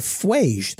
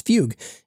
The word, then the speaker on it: Fugue: Enterprise cloud risk is a very real thing, so I found a post from Josh Stella, who's the CEO of Fuge, though he pronounces it as Fugue, and it makes some excellent points and also cites me.